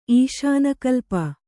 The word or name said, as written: ಈಶಾನಕಲ್ಪ